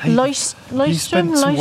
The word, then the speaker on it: Leuchtturm: Just say Leuchtturm.